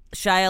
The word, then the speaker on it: Shia: First name rhymes with Maya. – Shia LaBeouf.